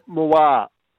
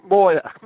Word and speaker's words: Moir: And uh, you just told me how to pronounce his name, Moore. – Moir? – Moir. M M Moyer Moyer M O I R Ashton, another bottom major.